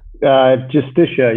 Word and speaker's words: Justitia: He is also a Danish lawyer, a human rights advocate, and a social commentator, and he is the founder and director of Justitia. How do you say that, Jacob? – Justitia, yeah, probably. – Justitia. Yeah, I guess that is the English way.